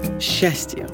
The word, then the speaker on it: счастье: Сейчас в русском языке масса буквенных сочетаний, которые произносятся как «ща». Например, «СЧ» – «счастье», «СТЧ» – «жёстче», «ЗЧ» – «резче», «ЗДЧ» – «объездчик», а также «ШЧ» – «веснушчатый» и «ЖЧ» – «мужчина».